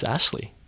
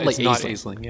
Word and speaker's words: Aisling: And I believe her name is pronounced Ashley. – Okay. – At least I wrote it down at some point because it's not Aisling.